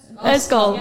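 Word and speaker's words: ysgol: And that's how I know, I don't know how you say it, but is it Eskol? Is it? – Eskol, yeah.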